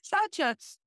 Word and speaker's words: Saucha: Saucha? Yes? – Yes. – Did I say it right? Yes. Saucha.